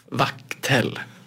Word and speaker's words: vaktel: Alltså uttalas det så? Vak, det är inte vack. Nej, det är vack. Det är vack? Ja. ja. Vaktel.